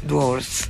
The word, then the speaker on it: dwarves: D-W-A-R-V-E-S. Questo esatto. E si pronuncia? – Dwarfs.